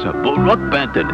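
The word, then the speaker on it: Brook Benton: Brooke Benton is the way he pronounces it.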